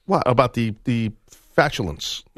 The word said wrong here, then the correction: flatulence: I said that word wrong. How do you say the flatulence. – Word? Flatulence. Oh, I said it right. – Okay. No, you didn't. You said fatulence.